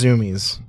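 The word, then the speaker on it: Zumiez: So like we went to the mall and then we checked out this place called Zoomies. – We had no idea how to pronounce it. Zoomies. – I think it's Zoomies.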